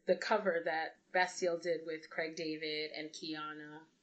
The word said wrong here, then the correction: Kianja: Oh, Kianja, actually. She confirmed that there's no silent J. It's Kianja.